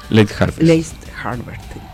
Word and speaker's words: late harvest: Es cierto que last harvest, así, ¿lo dije bien? – Late harvest.